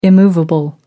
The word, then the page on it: immovable: American English